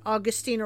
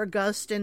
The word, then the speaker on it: Augustine: Many more aren't as notable, but Irenaeus, Origen, Augustine or Augustine, depending on how you're pronouncing it. I've heard it both ways.